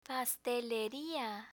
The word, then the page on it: pasteleria: Spanish